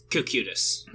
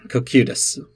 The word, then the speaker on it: Cocytus: Cocytus? – Okay, I've never known how to pronounce that. – Yeah, it's it's Cocutus. – Cocutus.